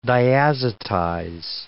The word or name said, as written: diazotize